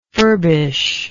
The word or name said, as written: furbisher